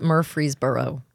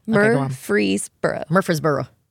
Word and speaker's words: Murfreesboro: Coming from the north, I can't say Murfreesboro. It's very difficult. – You did it very well. – I've gotten better about it. Murfreesboro.